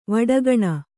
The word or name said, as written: ವಡಗಣ